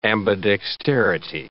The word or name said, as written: ambidexterity